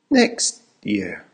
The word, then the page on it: next year: American English